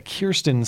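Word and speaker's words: Kyrsten: As of Saturday morning, it's Kirsten, I want to say it correctly, Kirsten Sinema, not Kristen, okay, Kirsten Sinema.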